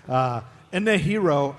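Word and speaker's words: Inejiro: His name is Inahiro, I-N-E-J-I-R-O. I think that's how you say it.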